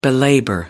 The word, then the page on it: belabor: British English